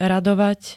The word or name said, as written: radovať